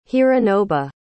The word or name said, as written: hironobu